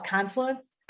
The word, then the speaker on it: Confluence: Well, I put an emphasis on the different part of the syllable. I call it confluence.